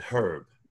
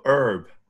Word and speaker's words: Herb: I said Herb. They said, how do you say that? – Huh? Exactly. – Like, then I go Herb and they're like, oh. – Oh, yep. Yep. – So the H is silent.